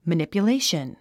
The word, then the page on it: manipulation: mənìpjuléiʃən; マニピュレイション